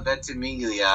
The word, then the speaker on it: Ventimiglia: The Rocky Balboa Jr. is played by Milo Ventimiglia. – Who played what? Say it again. – The older Rocky Balboa Jr. from the Rocky Balboa, the sixth one, and the Creed 2 is played by Milo Ventimiglia.